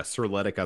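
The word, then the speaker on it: Serletic: Sirletic, I think is how you pronounce his last name.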